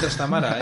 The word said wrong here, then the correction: Trastámara: ¿eh? sí, vamos a decir Trastámara.